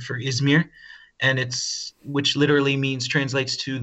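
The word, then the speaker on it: İzmir: Where you are now, Izmir, if I've said it correctly.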